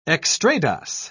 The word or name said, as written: extrados